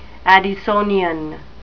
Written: Addisonian

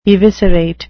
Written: eviscerate

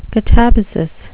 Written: katabasis

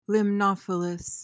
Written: limnophilous

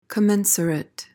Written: commensurate

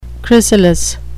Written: chrysalis

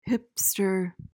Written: hipster

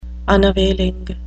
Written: unavailing

